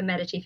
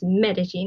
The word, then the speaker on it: Medici: Medici family. It's Medici, not Medici, but you know, whatever.